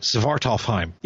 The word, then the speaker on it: Svartalfheim: Did I say that right? Svartalfheim. Yeah, there you go.